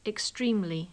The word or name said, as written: extremely